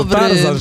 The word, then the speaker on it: Tarzan: To Tarzan, że niby RZ, ale nie czyta się jako Ży, tylko Rzy.